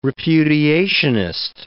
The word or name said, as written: repudiationist